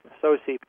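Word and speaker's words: Tsosie: I don't know how to pronounce his last name. It's T-S-O-S-I-E, Sosie, who's a traditional Navajo silversmith and artist who began receiving spontaneous dreams and visions in 1986.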